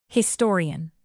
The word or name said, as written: historian